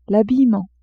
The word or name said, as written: habillement